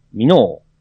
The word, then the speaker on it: 美濃: 地名のアクセント違和感はあるあるですね。今期の大河ドラマが地元なので、家族が見るのをチラ見程度ですが、美濃のアクセントが気になります。他の番組等での発音も、なぜか、美濃ですが、地元民は、美濃って、発音してるんですけどね。